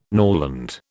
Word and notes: norland: British English

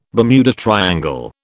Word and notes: Bermuda triangle: British English

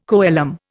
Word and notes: coelom: Indian

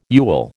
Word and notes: yule: American English

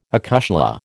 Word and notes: acushla: American English

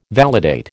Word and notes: validate: American English

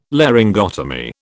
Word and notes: Laryngotomy: British English

